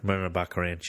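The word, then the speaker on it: Morena Baccarin: Marina Baccarin.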